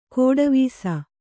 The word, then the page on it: ಗೆಡೆವಸು: kannada